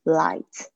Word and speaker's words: light: 好，那我们讲到香味的话，就会讲到 light scent 或者是 strong scent，就是说有浓有淡的，所以我们要注意怎么样子去形容味道很淡的，就是 light，不要记得这个 ght 不发音。